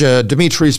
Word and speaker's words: Dimitri: But if you want to see it, go to my Facebook page, Dimitri spelled with three I's, D-I-M-I-T-R-I.